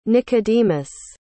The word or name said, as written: Nicodemus